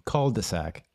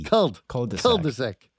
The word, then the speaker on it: cul-de-sac: Cul-de-sac. Cul-de-sac. It's a D. – Cul-de-sac.